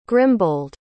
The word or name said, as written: grimbold